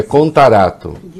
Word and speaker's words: Contarato: Acusando o presidente de se omitir, né, nesse processo todo da Covaxin, o que é, a minha mulher corrige, é Contarato. Eu falei errado? Eu falei Cantarato? Uhum. Não, é Contarato.